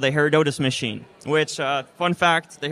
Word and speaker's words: Herodotus: How did you guys come up with the need for Herodotus? How do you pronounce it? – Herodotus.